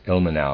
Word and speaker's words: Ilmenau: Ilmenau, I'm going to pronounce it that way, it's I-L-M-E-N-A-U, Ilmenau.